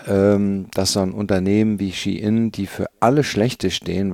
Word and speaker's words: Shein: Schein oder Nichtschein, das ist natürlich nicht korrekt ausgesprochen, weil der Konzern, über den es geht, um den es geht, wird eher ausgesprochen Shein.